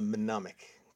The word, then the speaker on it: mnemonic: Several different pronunciations there, but I think you're all in the right line. And it's a mnemonic, correct. Mnemonic.